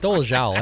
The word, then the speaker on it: Dolezal: KCC says Rachel Dolezal. It's Dolezal. Everyone's been saying it wrong, too.